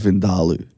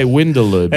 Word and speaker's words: vindaloo: And it's actually pronounced Vindaloo. – I think you'll find it's Vindaloo. – Yeah. – Fucking idiot. This cunt doesn't know how to speak English. – If you're my mum, you would say Windaloo because she can't pronounce V's and W's properly.